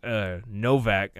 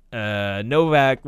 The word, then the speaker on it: Nowak: Okay, the film's loosely based on astronaut Lisa Nowak's Novak. I don't. I guess you might pronounce if it's German, you pronounce the W as a V.